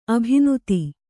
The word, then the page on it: ಅಭಿನೂತೆ: abhinūte